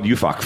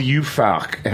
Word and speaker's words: Juhfark: And since then, and you had another wine called Ufok from the from Hungary, which from I had to call up Channel 13 and, like, get it cleared. It's a true story. So it's spelled Ufok. How do you spell it? How do you spell it? – J-U-H-F-A-R-K.